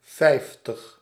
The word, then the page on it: vijftig: /ˈfɛi̯ftəx/; /ˈvɛi̯ftəx/